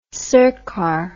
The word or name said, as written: sircar